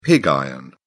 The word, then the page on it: pig iron: British English